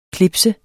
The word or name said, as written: clips